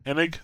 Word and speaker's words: Hennig: Hennig is leaving Naughty Dog. Did I say her name right? – I think just Hennig, yeah. – Hennig? Okay, there we go. That was close.